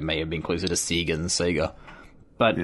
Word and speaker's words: Sega: It's weird how the internet's kind of destroyed the Australian pronunciation of Sega. Because it actually says Sega in like all of the ads and shit we had as a kid.